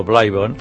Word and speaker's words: Oblivion: El títol d'Oblivion, es pronuncia Oblivion, no Oblivion, aquest títol...